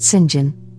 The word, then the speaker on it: St. John: Their names are Mary, Diana, and St. John, pronounced Sinjin, rivers, and Jane quickly becomes friends with them.